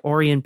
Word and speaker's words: Orion: I believe that's pronounced Orion. – Oh, is it Orion? – Yes. – What did I say? I don't even know what Orion. – I said. Oh, Orion. Okay.